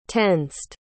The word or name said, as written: tensed